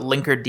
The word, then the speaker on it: Linkerd: Could you explain what Linkerd is? – Yeah, so Linkerd, and thank you for pronouncing it like that.